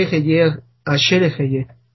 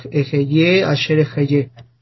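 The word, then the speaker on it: Heye Asher Heye (אהיה אשר אהיה): Nosotros lo leeríamos así, porque la hey que aparece ahí, algunos la pronunciaremos como si fuera una J delgada, no fuerte, sino una ligera J. Entonces diríamos el Moshe Heye Asher Heye.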